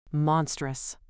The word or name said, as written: monstrous